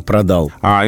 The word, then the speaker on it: продала: То же самое с продал лучше, чем продал. А, и только продала.